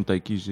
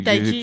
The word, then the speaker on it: Tayc: J'espère que je ne suis pas en train d'écorcher son nom parce que ça s'écrit T A Y C. Et vu que je ne l'ai pas encore entendu lui-même dire son nom, je prononce tel que je pense que ça se prononce. – Désolé, dans, hein. dans, dans, les sons, il dit quand même Taiki. – Il dit Taiki.